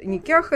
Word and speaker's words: никах: которая действительно осуществляет вот никях этот, или никах по-разному произносится,